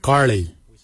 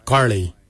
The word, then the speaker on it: giilyi: Darb is spelled D-H-A-A-B. It is a traditional knife, a darb. For boomerang, we say karli. Karli is the traditional name for boomerang. Karli is spelled G-I-I-L-Y-I, karli. For the traditional stone axe, we say gotch, gotch. Gotch is spelled G-O-O-D-J, gotch.